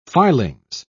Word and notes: filings: British English

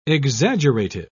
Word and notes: exaggerative: British English

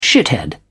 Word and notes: shithead: British English

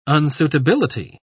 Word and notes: unsuitability: British English

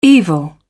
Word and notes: evil: British English

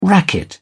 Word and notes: racket: British English

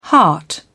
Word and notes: heart: British English

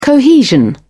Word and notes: cohesion: British English

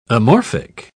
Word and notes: amorphic: British English

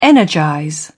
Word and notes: energize: British English

